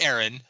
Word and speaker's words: Eron: Well, it's pronounced Aaron, but it's spelled E-R-O-N.